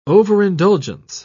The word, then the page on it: overindulgence: British English